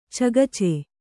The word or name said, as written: ಚಗಚೆ